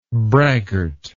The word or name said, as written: braggartly